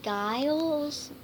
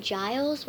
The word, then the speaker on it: Giles: It's really pronounced Giles with a J. Giles with a J. It rhymes with miles.